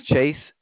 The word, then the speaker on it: Chase: My name is Chase, C-H-A-S-E. – C-H-A-S-E, Chase.